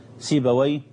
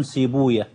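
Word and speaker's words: سيبويه: أهل اللغة ينطقونها كما أنطقها الآن، سيباوي باباوي نفطاوي بفتح الواو وإسكان الياء، وأهل الحديث لا ينطقونها كذلك، إنما ينطقونها يفرون من نطق وي، لأنهم يرون حديثا ضعيفا لا يثبت إسناده بأن وي اسم شيطان، ولذلك وحتى وإن ضعف الحديث فإن بعض أهل العلم أو كثير منهم صراحة يحتاط يعني، ولذلك ينطقونها على خلاف ذلك، فيقولون سيبويا وبابويا ونفطوية وحموية وهكذا، فرارا من أن يقولوا ويه يعني.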